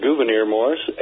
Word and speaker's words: Gouverneur Morris: It says that the final draft of the Constitution was crafted largely by Governor Morris of Pennsylvania. – Okay. It's actually pronounced Gouverneur Morris, and you are absolutely correct.